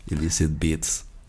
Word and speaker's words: Illicit Beats: Ça donc avec Alchemia et aux manettes, la production, c'est Illicit Beats, je ne sais pas si on prononce comme Alors, c'est à l'anglaise, mais j'aurais du mal à le prononcer, Illicit Beats. – Beats. – Exact, Illicit Beats.